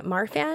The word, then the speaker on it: Marfan: Because Johan also has a syndrome that I might be pronouncing wrong, Marfan? – That's correct.